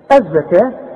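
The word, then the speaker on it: الزكاة: واتوا الزكاه، الزكاه بتكتب بالواو دائما في القران وتنطق بالالف، نحول الواو الى ايه؟ الف، الزكاه، خلاص.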